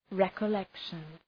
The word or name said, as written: recollection